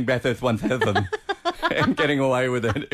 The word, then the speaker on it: Bathurst 1000: Well, you missed the Bathless 1000.